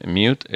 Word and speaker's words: Mude: Nosaltres pronunciem Mute, és una M-U-D-E. – Mm -hmm. – eh, hi ha qui ho pronuncia Mude, eh, nosaltres li diem Mute.